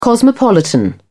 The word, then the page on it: cosmopolitan: British English